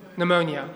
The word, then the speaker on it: pneumonia: Pneumonia. Oh, silent P. It is pneumonia.